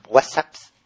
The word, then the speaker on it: WhatsApp: Se ha abierto un grupo de WhatsApp. ¿Se dice así? – WhatsApp.